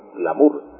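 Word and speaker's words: glamur: glamour. Pero se escribe en español con U, glamour. No se escribe glamour, sino glamour. La palabra glamuroso es común también en América y en España.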